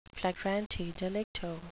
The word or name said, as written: flagrante delicto